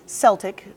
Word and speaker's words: Celtic: And if you want to simply Google Celtic or Celtic, as you might want to, both pronunciations of the word are correct, but C-E-L-T-I-C.